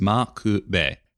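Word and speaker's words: M'Quve: You're probably not used to hearing the name of the New Zealand antagonist pronounced like McVeigh. And that's fair, especially when you see it spelled M apostrophe Q-U-V-E. But the Japanese original for his name is Ma Ku Be. In fact, prior to 2000, his name was usually transliterated as Makube, spelled M-A-K-U-B-E, with the emphasis on the ma part of the name.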